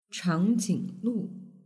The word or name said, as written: changjinglu